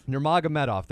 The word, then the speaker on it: Nurmagomedov: And Thiago Tavares, he is 4-1-1 in his last six, and he takes on undefeated Khabib Nurmagomedov, I think. Nurmagomedov, I believe is how you pronounce it. He's a Russian undefeated fighter. – Nurmagomedov, I believe. – Nurmagomedov, there we go.